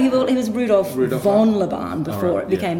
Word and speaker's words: Laban: Interesting that we start with Laban, and I'm going to transfer it into the Hungarian pronunciation, which is Laban. So being Hungarian, Laban. Here we go.